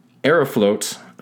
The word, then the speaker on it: Aeroflot: It is A-E-R-O-F-L-O-T. So it's Aeroflot Flight 593. – Uh-huh. – That has a little bit of a better than Aeroflot. – Yeah. – Anyway, Aeroflot Flight 593 was a regular passenger flight from Sheremetyevo.